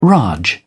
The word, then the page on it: raj: British English